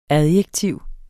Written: adjektiv

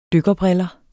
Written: dykkerbriller

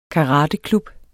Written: karateklub

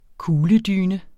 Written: kugledyne

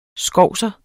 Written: skovser

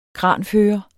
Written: kranfører

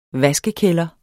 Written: vaskekælder

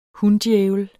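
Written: hundjævel

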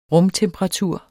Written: rumtemperatur

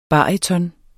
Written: baryton

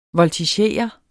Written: VOLTIGEREDE

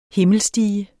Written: himmelstige

